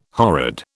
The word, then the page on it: horrid: British English